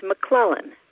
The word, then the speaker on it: McClellan: It is pronounced McClellan, which rhymes with melon.